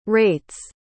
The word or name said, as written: rate's